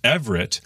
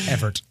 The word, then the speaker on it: Evert: And those who are fully matured spiritually, you know, more they have a strong ground to stand on in their faith, Evert would be the correct pronunciation, so we can avoid a need for another ecumenical council. Yeah. Just settle it right there. Evert.